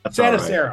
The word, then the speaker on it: Santarsiero: It's close. It's Santarsiero. – Santarsiero.